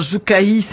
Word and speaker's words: Joukaï: Ah, avec vous, on va découvrir l'ONG Joukaï. J'ai bien prononcé, j'espère. – Non, Joukaï, c'est en français.